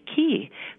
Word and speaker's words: ki: And I've recently been writing and thinking about this, and the proposal for another pronoun is to use pronoun K-I, pronounced ki.